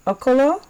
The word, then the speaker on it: Okolo: Okolo, right? – Thank you so much.